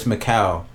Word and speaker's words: Mikal: I always say that too, Malik Beasley, but it's Mikal. – I said it right. – M-I-K-A-L. Mikal Beasley.